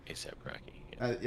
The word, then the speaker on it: ASAP Rocky: How you say his name? ASAP Rocky? I don't know. I'm not a rap guy, can you tell? – That's right. I think that sounds right. ASAP Rocky.